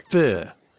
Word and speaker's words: pho: He's like, no, it's pho. Pho.